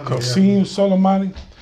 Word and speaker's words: Qasem Soleimani: Qasem Soleimani.